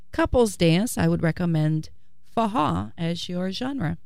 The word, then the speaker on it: forró: What we call the music is forró. It's spelled F-O-R-R-O, forró.